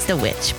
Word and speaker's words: The Vvitch: It's The Witch, but you know, it's spelled with V's.